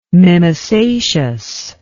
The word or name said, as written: mimosaceous